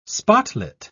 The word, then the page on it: spotlit: British English